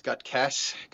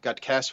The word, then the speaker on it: Gutkes: The only thing I'm stuck on right now is you mispronouncing my last name. It's Gudkes. Gudkes for everybody else.